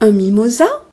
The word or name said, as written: mimosa